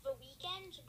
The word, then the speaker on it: The Weeknd: Yeah, The Weeknd, you know? The Weeknd, like, they're called The Weeknd, but it's spelled Weeknd.